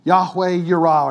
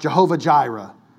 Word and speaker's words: Yahweh Yirah: Yahweh Yirah, or you might hear it pronounced Jehovah Jireh.